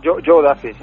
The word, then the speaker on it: Joe Duffy: Es de Joe Duffy, corrígeme, si no pronuncio correctamente. – Sí. – Joe Duffy, ¿no? Estaba bien dicho. – Joe Duffy, sí.